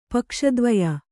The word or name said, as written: ಪಕ್ಷದ್ವಯ